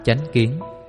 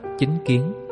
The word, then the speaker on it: chánh kiến: Thực hành chánh kiến. Chánh kiến tiếng Phạn là Samajithi, đôi khi cũng đọc là chính kiến, với ý nghĩa là quan niệm, cách nhận hiểu vấn đề một cách chân chánh.